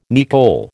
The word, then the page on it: nicol: American English